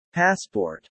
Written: passport

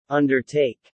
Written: undertake

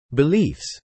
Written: beliefs

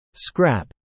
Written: scrap

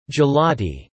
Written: gelati

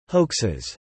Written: hoaxes

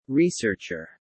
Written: researcher

